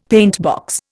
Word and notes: paintbox: Indian

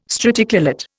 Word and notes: straticulate: Indian

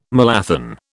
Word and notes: malathion: British English

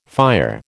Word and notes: fire: American English

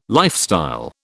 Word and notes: lifestyle: British English

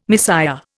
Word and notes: misère: Indian

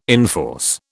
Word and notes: enforce: British English

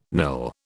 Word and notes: nil: British English

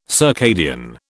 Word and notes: circadian: British English

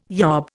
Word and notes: yob: Indian

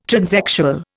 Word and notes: transexual: Indian

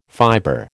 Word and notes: fiber: American English

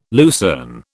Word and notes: lucerne: British English

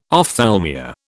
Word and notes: ophthalmia: British English

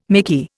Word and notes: micky: Indian

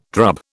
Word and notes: drub: British English

